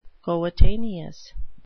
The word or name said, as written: coetaneous